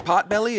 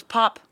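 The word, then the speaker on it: Potbelly: A pot belly? – Oh, that's just a restaurant in airports. – Isn't it pot belly? It is pot belly. – I thought it was pop.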